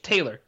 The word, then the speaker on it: Taylor: The hassle I had, my last name is Taylor, uh-huh. And in Korea, in English, Taylor, 2 syllables, yeah. Taylor. Yeah. In Korean, it's 4. It's Taylor. It's just, it's too much.